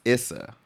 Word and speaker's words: Issa: It's actually pronounced Issa. – Issa. – So it's short for It's A.